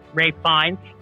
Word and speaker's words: Ralph Fiennes: Okay, Rafe. Rafe. Rafe Fiennes. – I don't know. I've got an uncle. He spells his name the same way. His name's Ralph. – My father-in-law is named Ralph, and it's spelled the same too, but whatever.